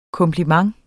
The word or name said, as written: KOMPLIMENTENS